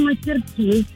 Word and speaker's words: мастерски: Ну, вообще, я говорю мастерски на последнее «и».